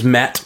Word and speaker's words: Matt: Every time I say my name's Matt Carroll, yeah. People think I said Mac Carroll because Matt Carroll, Mac, Mac Carroll, Matt Carroll, Matt Carroll, Matt.